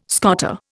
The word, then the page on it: scoter: Indian